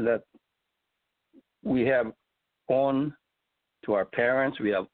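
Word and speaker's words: on (恩): It said in Japanese, there's a word called on. It's O-N, but it's pronounced on.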